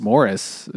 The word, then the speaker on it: Maurice: They said it's just Morris. – It's spelled Maurice, and I just kept thinking of the fucking Bee Gees, because that's the only time I've heard of a man named Maurice.